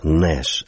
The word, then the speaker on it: Нэш: Может быть, нужно было там тоже звездочку поставить, потому что произносится все-таки это Нэш, а не наш.